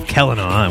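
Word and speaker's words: Celanawe: This is the story of Kelena, and we know that's how his name is pronounced because we interviewed David Peterson, the creator, last week. That's what he said.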